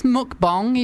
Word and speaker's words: mukbang: There's literally, because when I first heard about mukbangs as well, mukbangs. – I think it's mukbong, even though it's spelled mukbang.